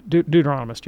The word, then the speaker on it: Deuteronomist: And now I know how to pronounce Deuteronomist. – Deuteronomist, yeah.